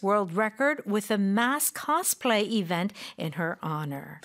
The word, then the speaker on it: cosplay: In Korea, people say cosplay. – Yeah. – In English, Americans say cosplay. – Cosplay. Yeah, play까지 play. – 해야죠. – Yeah, cosplay. – 프레 이렇게 끝내면 안 되는 거죠.